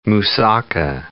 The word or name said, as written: moussaka